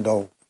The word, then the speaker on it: Doe: La hace John Doe. John Dewey. Sí, Dewey o Doe. – como nadie. Se escribe Dewey. No, se escribe D-O-E, que es como John Nadie.